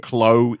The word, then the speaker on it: Klowee: K-U-H-L-E-E. Oh, no. L-O-E-E. – I'm going to go K-K-Kaloi. K-A-L-O-W-Y. – No, this one is spelled K-L-O-W-E-E. – K. – Chloe. – Oh, we. Nice. With a W.